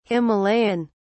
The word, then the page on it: himalayan: British English